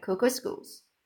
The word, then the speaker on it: Kookooskoos: Anyway, welcome to Camp Kookooskoos. It rhymes with moons.